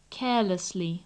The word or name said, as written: carelessly